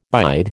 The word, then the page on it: bide: American English